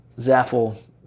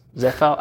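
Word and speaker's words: Zéfal: Shout out to the Zaffle Magnums. Zephal? I don't know how to say it. – Zephal? – It's French.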